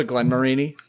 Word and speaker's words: Glenmorangie: Is it still the Glenmorangie? – Yeah, there we go.